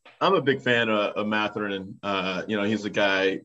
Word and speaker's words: Mathurin: And then they don't really see any of the top-tier rookies until December, when they're going to see Canada's own Benedict Mathurin of Indiana. What do you think of Mathurin? Am I saying that right? – Mathurin.